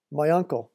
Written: my uncle

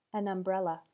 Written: an umbrella

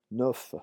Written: neuf

